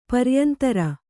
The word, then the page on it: ಪರಿಯಂತರ: pariyantara